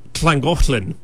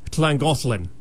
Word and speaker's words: Llangollen: There was one town called L-L-A-N-G-O-L-L-Y-N. I'm like, I got to find out how to say that. – And how do you say that? – It's Llangollen. – Wow. – Yeah. – How was that again? – Llangollen.